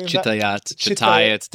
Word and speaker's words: Shtayet: Shtayet. Depends on where you are, what language you're speaking.